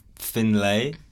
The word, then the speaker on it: Finlay: My name's Finlay. – Finlay. – But people like the normal is Finley, right?